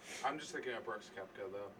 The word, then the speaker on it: Koepke: I think it's, I want to say it's pronounced Koepke. – Might be. – It's K-O-E-P-K-E, or, yeah, P-K-E. K-O-E-P-K-E. – Yeah, I want to say it's Koepke.